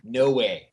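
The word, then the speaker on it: Nowe: Yes, so, our hero is named No Way. That is how it is pronounced.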